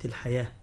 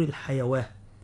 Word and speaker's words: الحياة: كذلك كلمة الحياة الدنيا، كلمة الحياة الألف رسمت فوق الواو، لكن ننطقها ألف لا ننطقها واو، لا نقول الحيواة، تمام.